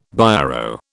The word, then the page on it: Biro: British English